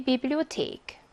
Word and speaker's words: Bibliothek: 중앙역 옆에 위치하고 있습니다 라고 했고 테하 라고 하는 복자음은 영어랑 헷갈리면 안 된다 라고 했어요. 테하는 독일어에서 무슨 발음이죠? 트 발음을 내는 경우예요. 그래서 Bibliothek 이라고 합니다. 이 Bibliothek 이라고 하는 단어는 강세도 주의하셔야 돼요. 강세 여기 있거든요.